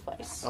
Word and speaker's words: Weisz: Rachel Weisz. I hope I said that right. – Rachel Weisz. – Yeah, her name is Vice.